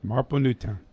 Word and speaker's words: Marple Newtown: But history teacher from, is it Marple Newtown? Am I saying that right? – Marple Newtown. – Marple Newtown.